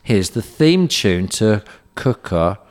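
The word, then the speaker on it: Quooker: Yeah, this podcast is sponsored by Cooker, spelt Quicker, pronounced Cooker.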